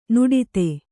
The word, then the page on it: ನುಡಿತೆ: nuḍite